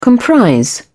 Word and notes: comprise: British English

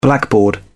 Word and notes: blackboard: British English

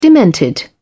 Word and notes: demented: British English

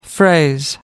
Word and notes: phrase: American English

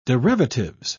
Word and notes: derivatives: British English

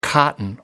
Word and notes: cotton: American English

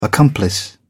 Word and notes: accomplice: British English

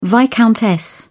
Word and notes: viscountess: British English

